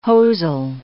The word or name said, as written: hosel